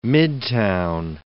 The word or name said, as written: midtown